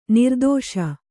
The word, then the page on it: ನಿರ್ದೆಸೆ: nirdese